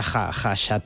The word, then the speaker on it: Khachaturian: Aram, se escribe K-H-K-H-A, pero se pronuncia Khachaturian.